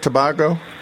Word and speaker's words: Tobago: Before she goes to Tobago, is that how you pronounce that, Tobago? – That's correct, yes.